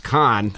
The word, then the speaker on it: Kahn: Apparently, his last name's pronounced con, which I found out 30 seconds ago.